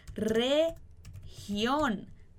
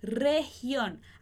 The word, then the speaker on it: región: Región. Región. Aqui a gente pode, às vezes, até confundir um pouco com o português, porque esse R no início da palavra em português a gente vai pronunciar ha.